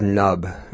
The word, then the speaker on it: KNUB: That was Nub, who it's spelled K-N-U-B, if you're Googling.